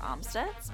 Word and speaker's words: Amstutz: And all of my social media is at Sandra Amstutz. My last name is spelled A-M-S-T-U-T-Z.